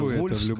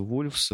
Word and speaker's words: VLVS: Пишется VLVS, короче. Произносится Вольфс, короче. Случае. – Произносится Вольфс.